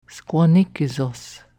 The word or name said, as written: Squanni kesos